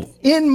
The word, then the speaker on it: poutine: And it says, on the t-shirt, it says P-U-T-I-N. And that's how you say it, put in. And the way you remember it is, put in my belly.